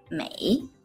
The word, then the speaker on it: 每: Se pronuncia mei.Y normalmente siempre sigue la siguiente estructura.